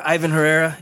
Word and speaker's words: Ivan Herrera: Yeah, Ivan Herrera.